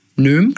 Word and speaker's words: Noem: Noem, glaube ich, ausgesprochen. Mhm. Noem geschrieben.